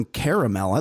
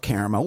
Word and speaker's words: caramel: I love caramel. Where are we at on caramel? Are we caramel or caramel? – Caramel. – Caramel. – Oh, yeah. Some people just pronounce it like it's got less letters.